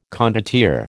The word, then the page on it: condottiere: American English